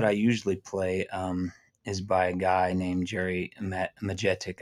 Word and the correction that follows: Majetich: I never can say his name. It's Majetich. But Jerry, we're no pressure, but we were actually recording.